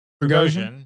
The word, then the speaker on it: Prigozhin: Prigozhin.